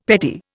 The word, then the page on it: petty: Indian